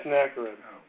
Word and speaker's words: Sennacherib: Hear the words of, well, I'm not going to be good at pronouncing this, Sennacherib. How do you pronounce that? – Sennacherib.